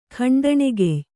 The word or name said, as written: ಖಂಡಣೆಗೆಯ್